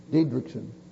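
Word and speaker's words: Didrikson: How you pronounce her last name? Diedrich? What is it? Diedrichson.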